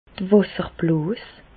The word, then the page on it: d'r Wàsserblos: Alsatian; Bas-Rhin; Reichshoffen